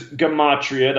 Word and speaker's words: gematria: So the subject is gematria. That's the proper way to say the word. People who follow me hear me say it. – That's what I said. – Gematria all the time because that's the way I learned it before I was corrected.